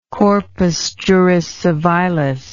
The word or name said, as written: corpus juris civilis